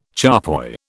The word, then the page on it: charpoy: British English